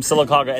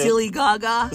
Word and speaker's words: Sylacauga: That's the real name, Sylacauga, and that's the correct pronunciation.